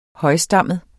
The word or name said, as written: højstammet